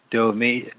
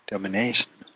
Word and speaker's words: Domitian: Domitian. Domi, Domi, domination.